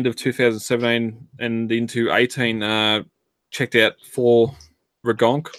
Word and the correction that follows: Ragnarok: For English listeners, Ragnarok.